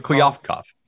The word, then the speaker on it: Kliavkoff: Kliavkoff. – Pronunciation we're going to go with? Kliavkoff. – Yeah.